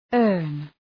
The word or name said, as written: earn